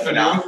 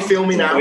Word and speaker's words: Finau: How'd you pronounce him? Finau. – Finau. – Yeah, Finau. – Finau. Finau. – Yeah. Last episode was Tony. Feel me now.